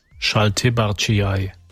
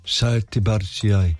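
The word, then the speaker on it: šaltibarščiai: Schaltibarciai. – Schaltibarciai. – Schaltibarciai. – Okay, litauische Speise, Klammer auf, kalte Suppe mit rote Beete und Kartoffeln. Hier. – Schaltibarciai.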